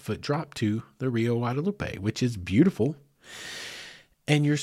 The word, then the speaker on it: Guadalupe: But what I'm here to tell you is you reach a point where you reach what is known as the Guadalupe Box, or Guadalupe Box, which would be the more proper pronunciation.